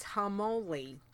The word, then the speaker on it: Harmoli: 6 is Harmoli. Thanks, Nisi. Level 27 RIP. She's written in brackets, rhymes with holy moly.